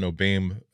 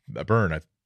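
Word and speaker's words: O'Beirne: There's a cartographer by the name of Justin O'Beam O'Byrne.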